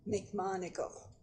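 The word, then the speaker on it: McMonagle: McMonigle. – Spell that for name. – Me. – M-C, capital M-O-N-A-G-L-E.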